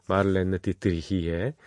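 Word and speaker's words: Marlene Dietrich: 네, 마를렌 디트히의 발음이 좀 힘듭니다. 마를렌느 디트리히의 예, 노래였습니다.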